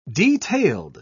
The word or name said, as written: detailed